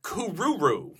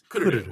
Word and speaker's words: Kururu: Yeah, and it's not – people look at it and they're like, Kururu. – No. – No, it's Kururu with the accent on the first syllable.